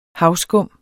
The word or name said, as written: havskum